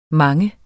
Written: mangen

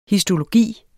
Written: histologi

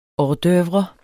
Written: hors d'oeuvre

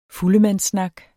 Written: FULDEMANDSSNAKKEN

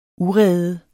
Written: urede

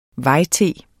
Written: vej-T

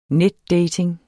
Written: netdating

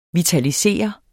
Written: vitalisere

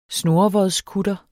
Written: snurrevodskutter

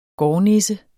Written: gårdnisse

